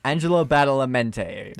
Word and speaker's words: Angelo Badalamenti: Angela Badalamenti. – Yes, that. – That's Angela Badalamenti. – Yeah, Angelo Badalamenti. – Angelo Badalamenti. – There you go.